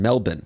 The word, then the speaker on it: Melbourne: We just booked an Airbnb in Melbourne.